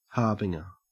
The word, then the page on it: harbinger: Australian English